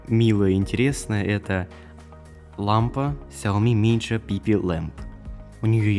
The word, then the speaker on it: Xiaomi: Также 18 апреля, во вторник, всем известная компания Xiaomi, да, именно так это произносится правильно, представила новую продукцию.